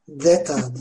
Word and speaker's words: zeta: Okunuşu zeta aslında peltek okunuyor o da. Deta diye. Deta diye.